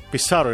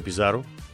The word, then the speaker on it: Πισάρο: Πισάρο είναι, παιδιά, θα το τονίζω το σου, θα το λέω λίγο παχύ. Γιατί δεν είναι Πιζάρο ή κάτι τέτοιο. Πιτσάρο και δεν ξέρω τι.